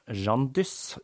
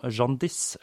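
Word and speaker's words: Jeanne d'Ys: Her finner vi også en link til Den gule kongen, da navnet til den kvinnelige hovedpersonen, Jeanne Dyss, uttales på samme måte som Jeanne Diss eller Gullsot.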